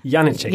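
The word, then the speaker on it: Janecek: Janetschek. Janetschek. Genau, ich, äh, genau die, die Betonung liegt auf der ersten Silbe.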